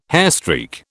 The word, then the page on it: hairstreak: British English